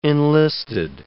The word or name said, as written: enlisted